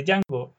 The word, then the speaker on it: Django: El actor, o sea, este juego que había hecho de Django en la película anterior, ahora le dice su nombre mal y mm. Jimmy Fox le dice The D silent. – Mm. – la D no se pronuncia.